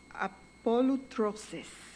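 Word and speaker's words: apolutrosis (ἀπολύτρωσις): The meaning of the word redemption from the Greek, it is pronounced apolutrosis.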